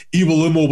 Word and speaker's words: Iwelumo: So that's what we've grown up with, Iwelumo. And obviously I'm 42 years old now. I'm not gonna change it, even though my sister did tell me Iwelumo was the right pronunciation.